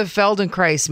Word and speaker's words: Feldenkrais: So what is the Feldenkrais method? Am I saying it right in the first place? – Yeah, you're saying it right. The Feldenkrais method, it's a German last name.